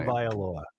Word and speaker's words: Tagovailoa: Tagovailoa is his last name. – Tagovailoa.